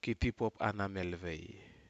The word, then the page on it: kitipupanamelvai: Aneityum